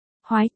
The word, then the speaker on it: Hồi: Ở đây tên của nữ chính là Nguyệt Hồi, Hồi phát âm là Hoái, chữ Hoè trong cây Hoè phát âm là Hoái, hắn nói, là Hồi trong Bồi Hồi.